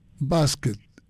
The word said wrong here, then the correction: Bastet: Bastet. – ¿Cómo se escribe? – B-A-Bastet. – Bastet. – Bastet. B-A-S-T-E-T, Bastet.